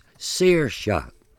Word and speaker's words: Saoirse: Without further ado, the name is Saoirse, meaning freedom, and pronounced Saoirse.